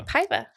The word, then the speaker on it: Paiva: We get Piva, Paiva. – Because it's spelled Pova. A-I-V-A. Correct. – Looks like you say it how it sounds. – Paiva. Yeah, exactly. Paiva. Yeah.